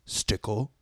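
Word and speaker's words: Stickell: Key operatives: Hunt, Ethan, Stickle, Luther.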